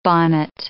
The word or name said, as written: bonnet